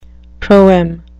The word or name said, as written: proem